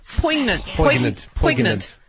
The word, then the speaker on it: poignant: Poignant. – She pronounced the G.